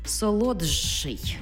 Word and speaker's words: солодший: Тобто пишемо «солодший», а вимовляємо «солодший».